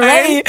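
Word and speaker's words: Raye: Reihe, so spricht man sie aus, ne? – Scheiße, ist ja noch nicht mal aufgefallen. – Nein. – Ray, nein. Du hast stumm vorgelesen. Ray.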